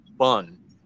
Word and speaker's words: Bon: And before that, I never heard of what that civilization was, but I heard the name Bun, B-O-N, but it's pronounced like a hot dog bun.